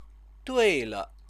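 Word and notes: 对(了): duì(le)

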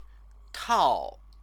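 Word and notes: 套: tào

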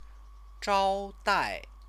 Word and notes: zhaodai: chinese

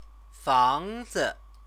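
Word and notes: 房子: fángzi

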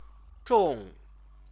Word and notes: zhong: Chinese

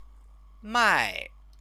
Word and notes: mai: chinese

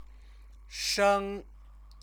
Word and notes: sheng: chinese

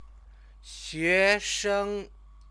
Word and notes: xuesheng: Chinese

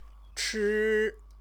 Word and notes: chi: Chinese

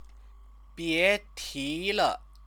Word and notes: bietile: chinese

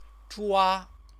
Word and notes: zhua: Chinese

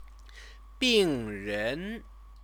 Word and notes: bingren: chinese

